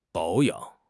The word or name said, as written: 保养